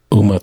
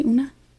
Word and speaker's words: Uma: Uma Thurman. – What did I say? Una? – It's, yeah, he's like.